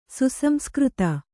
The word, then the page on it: ಸುಸಂಸ್ಕøತ: Kannada